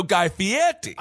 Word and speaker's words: Guy Fieri: Yeah, Fieri. Well, you're still not saying the name right, though, Guy Fieri. – I will not. – Okay. – I will not give him the Italian lilt, but I will give him the benefit of the doubt that he has not touched anyone against their consent.